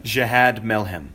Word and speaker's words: Jihad Milhem: Jihad Milhem.